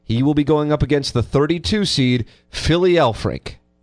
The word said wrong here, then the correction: Philip: P-H-I-L as in Laura. – Yeah. – L as in Laura. I-P. – Oh, Philip. I thought you said Philly. – No, that's your Philip.